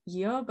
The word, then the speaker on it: Yeoh: I always say Yeoh, but I don't know. – Yeoh. Michelle Yeoh. – Yeoh. – That's what I thought. Yeoh. So yeah, Michelle Yeoh plays the main woman, and she's really amazing.